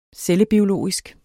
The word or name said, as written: cellebiologisk